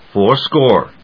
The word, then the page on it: fourscore: fóur・scóre